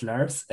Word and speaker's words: Lars: My name is Lars, and I'm working for Tech Division, a service provider or Adobe partner in south of Germany.